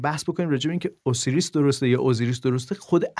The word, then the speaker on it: OSIRIS: آقا بالاخره اوسیریس رکس یا اوزیریس رکس شما اوسیریس میگین بهش من اوسیریس میگم به خاطر اینکه قابل جستجوتر بشه ولی اوزیریس تلفظ درسترشه